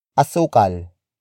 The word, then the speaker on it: asukal: Asukal. Asukal. Asukal is spelled as A-S-U-K-A-L.